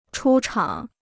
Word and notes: 出厂: chū chǎng

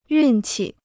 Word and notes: 运气: yùn qi

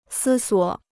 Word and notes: 思索: sī suǒ

